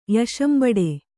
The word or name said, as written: ಯಶಂಬಡೆ